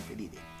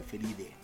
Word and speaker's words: Felidae: de la familia Felidae o Felide, ya que se pronuncia así Felide. Cuando termina con AE en el nombre científico se le pronuncia Felide.